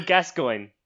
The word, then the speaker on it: Gascoigne: Paul Gascoigne.